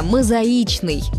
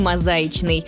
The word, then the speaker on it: мозаичный: Будем произносить его правильно – «мозаичный», никак не «мозаичный». Запомнить правильный вариант поможет один из мнемонических стишков, которые специально сочиняют для запоминания ударений.